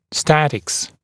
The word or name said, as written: statics